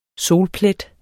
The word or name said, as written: solplet